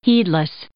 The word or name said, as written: heedless